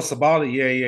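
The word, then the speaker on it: Sabally: Stasu Sabali? Sabali? – Oh, Sabali. Yeah. – I think she's the, like, she's athletic as shit too. – Stasu Sabali.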